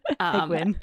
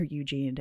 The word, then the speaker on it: Egwene: Like I said, yeah, in the previous episode, I called her Eugene until I started listening to the audiobooks, and that's very much not how it's spelled.